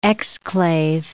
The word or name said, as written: exclave